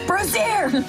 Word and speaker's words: brazier: You noticed you're pronouncing brazier weirdly. – Very good. – It's brazier!